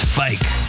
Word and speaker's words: Fike: It's Fike. You know, rhymes with bike. – Fike. – Dominic Fike.